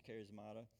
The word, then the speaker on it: charismata: Now, gifts is the word charismata. I probably butchered that, but in the Greek it's charismata, which we get our word charismatic.